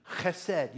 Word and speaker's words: chesed: It's a Hebrew word. It's kind of fun to say. Go ahead and say it, but try not to spit on the person in front of you. Chesed.